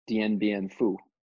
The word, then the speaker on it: Dien Bien Phu: Dien Bien Phu in 1954. – Can you say that one again for me, please? – The French troops surrendered to the Viet Minh in Dien Bien Phu.